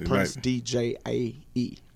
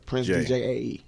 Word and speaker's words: Prince DJAE: Cause it's Prince DJAE. I was going to say I. – JAE. – Prince DJAE.